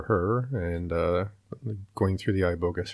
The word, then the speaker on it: iboga: Iboga, I-B-O-G-A.